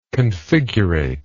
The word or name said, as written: configurate